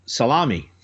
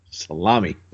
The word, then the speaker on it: Salome: About the pronunciation of salami. – Salome.